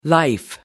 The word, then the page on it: life: American English